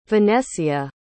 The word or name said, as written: venecia